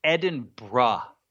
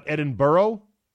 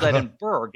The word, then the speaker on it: Edinburgh: It is spelled Edinburgh, and they hate that too. It's Edinburgh, and that's the way they want you to say it.